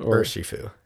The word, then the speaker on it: Urshifu: Urshifu.